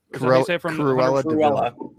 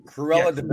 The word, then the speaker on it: Cruella de Vil: Number five, I'm pretty sure I'm going to butcher this, but it's, what is it, Cruella de Vil? – Cruella de Vil. – Cruella de Vil.